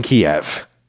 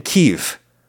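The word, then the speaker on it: Kyiv: It's basically Chicken Kiev. Or Chicken Kiev, I believe we're trying to say now, to favor the native Ukrainian pronunciation.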